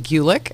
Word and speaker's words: Gulick: Amy did tell me it rhymes with Buick, but I didn't trust myself to say Gulick correctly on the first one. How do you spell your last name? – G-U-L-I-C-K.